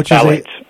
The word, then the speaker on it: phthalates: The, I don't know how to pronounce it, but phthalates? – Yeah, phthalates.